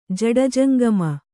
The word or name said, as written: ಜಡಜಂಗಮ